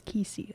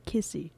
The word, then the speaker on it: nkisi: There she meets up with another young girl from a different time, and together they set out to find the magical, it is spelled N-K-I-S-I, so I might just say Kisi. Let's see how to pronounce this one too, shall we? – Alright. – It just says Kisi, or Kissy.